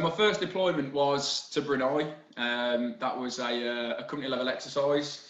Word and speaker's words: Brunei: I couldn't say it right in my intro. – Oh, the deployment to Brunei, yeah. – Brunei, that's this it.